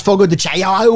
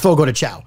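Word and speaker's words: Fogo de Chão: Okay, except for the one person that said it's pronounced Fogo de Chao or something. It's not. I just called three Fogo de Chao's and they all confirmed it's Fogo de Chao.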